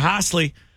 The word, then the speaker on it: Hosley: H-O, I said it right. Hosley.